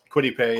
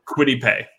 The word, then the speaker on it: Kwity Paye: DeForest Buckner, uh, they drafted Kwity Paye. Did I say that right? – Kwity Paye. – Yeah. Kwity Paye. Thank you.